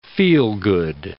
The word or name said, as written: feel-good